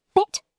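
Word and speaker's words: Bett: A short E in German sounds like the E in bed. So bet, meaning bed, is short and clipped.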